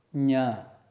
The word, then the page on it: ñ: sanskrit